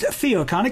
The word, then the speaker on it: Theocon: Theocon is spelled with a theta instead of a th diphthong. For our fellow Greek nerds, pronounced Theocon, it combines two words, theology and convention.